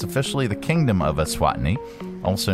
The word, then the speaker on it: Eswatini: Eswatini.